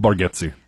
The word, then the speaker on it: Bargatze: I love that Bargatze, is that how you say his name? – Bargatze.